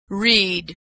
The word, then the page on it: read: pres